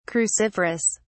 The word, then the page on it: cruciferous: British English